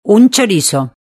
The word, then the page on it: un chorizo: European Spanish